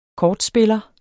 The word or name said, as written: kortspiller